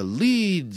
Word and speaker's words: Lied: And on February 11th, Cocktails with Larry Miller, same thing, in Lincoln, Nebraska, at the Leed Center. That's spelled L-I-E-D, but it's pronounced L-E-E-D. Remember, where the extra E is for value.